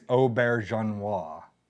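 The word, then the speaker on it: Auberjonois: Renee, I think I say Renee Aubert, but I think it's Aubergenois is how it is actually pronounced.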